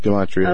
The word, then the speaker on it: Gematria: I went back in, and I don't know if I'm pronouncing this the right way, Gematria? – Right, that's fine. – Yeah. Gematria, okay.